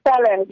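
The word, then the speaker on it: Kpelle: And you created a label by the name of Moi, which you said means talk in am I pronouncing it right, in Kipele? – It's called Fele. Yeah, Fele.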